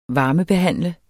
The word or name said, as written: varmebehandle